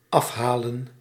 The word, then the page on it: afhalen: /ˈɑf.ɦa.lǝn/